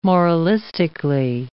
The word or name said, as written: moralistically